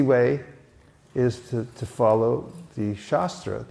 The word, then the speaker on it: shastra: And the other way is called shastra. It's just same spelling, only short A, and that means the sword.